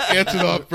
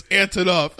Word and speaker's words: Antonoff: It's Jack Antonoff, bro. It's Antonoff. – On and off. – Jack on and off.